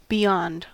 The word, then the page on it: beyond: American English